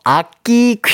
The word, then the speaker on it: 악기: 첫 번째 퀴즈는요, 일주일에 한 번 만날 수 있는 악귀, 아, 악귀래, 자꾸. 악귀 아니고요 악기 퀴즈입니다. 아니, 발음이 어렵네요.